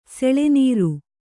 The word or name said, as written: ಸೆಳೆನೀರು